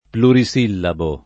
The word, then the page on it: plurisillabo: lingua italiana